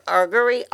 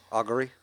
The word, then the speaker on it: augury: Um, how did we say that was Ar- argery? Argery. Augury.